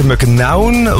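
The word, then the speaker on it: McKeown: Moeten we echt nog een beetje wennen aan de naam Max McKnown? Want het is eigenlijk uitgesproken dus McKnown.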